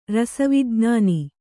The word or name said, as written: ರಸವಿಜ್ಞಾನ